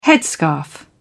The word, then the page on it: headscarf: British English